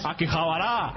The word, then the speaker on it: Akihabara: Oye, ¿pero qué has dicho Akihabara? Que es como Zaragoza, tiene un acento en todas las vocales. – ¡Akihabara!